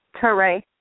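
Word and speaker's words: Taré: Hello, my name is Teray. I'm calling from Chicago. – Hey, Teray from Chicago. – Did you say Trey? – Teray. – How do you spell that? – T-A-R-E with a hyphen on the E.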